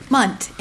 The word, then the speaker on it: Munt: Munt, M-U-N-T.